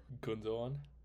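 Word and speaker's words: Gündoğan: Gundogan? How do you say it? – Gundogan.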